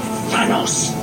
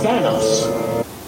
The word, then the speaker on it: Thanos: All because of Thanos. Thanos.